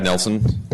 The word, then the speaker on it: Nelson: Hello, my name is Chad Nelson. Last name is spelled N-E-L-S-O-N.